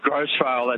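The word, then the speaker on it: Grose Vale: It is Grosvale, isn't it? – Oh, you can say that. Just Grosvale, that's all we say.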